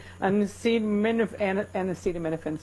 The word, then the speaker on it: acetaminophen: Acetafin. – What is that word? – Acetafin. – That's a hard one. How do you say it? Acetaminophen, sir. Acetaminophen.